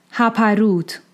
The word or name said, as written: Haparūt